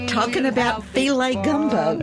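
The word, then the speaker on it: filé: as in filet gumbo. – Which is spelled F-I-L-E. I remember the first time I saw it in a recipe. Oh, yeah, the little accent.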